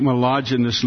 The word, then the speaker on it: myelogenous: Of course, when I called out here and told Joe, I said, "Joe, I have chronic molluggiosin," and of course, he immediately corrected me. He said, "If you're going to have the disease, at least know how to pronounce it." And so it's a chronic myelogenous leukemia, and he's amazing.